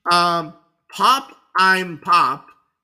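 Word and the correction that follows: Pop 'Im Pop: It's pronounced Pop'em Pop.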